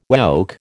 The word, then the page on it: woke: American English